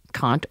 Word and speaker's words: Kant: So Kant, Immanuel Kant or Kant, depending on how you want to pronounce it,